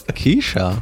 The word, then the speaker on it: Kesha: We're just watching the Kaiza Hideaway video. – You called her Kaiza? – Isn't that how you say it? – Keisha.